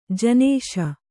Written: ಜಿನಶಿ

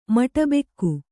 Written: ಮಟಬೆಕ್ಕು

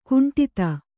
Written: ಖಂಡಿತ

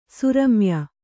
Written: ಸುರಮ್ಯ

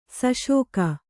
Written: ಶಾಸಕ

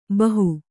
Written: ಭಾಹೊ

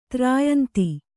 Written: ತ್ರಾಯಂತಿ

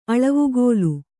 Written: ಅಳವುಗೋಲು

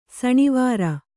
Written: ಸಣಿವಾರ

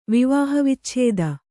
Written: ವಿವಾಹವಿಚ್ಛೇದ

